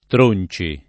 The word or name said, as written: Tronci